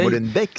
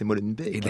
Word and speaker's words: Molenbeek: Pas Molenbeek, c'est Molenbeek.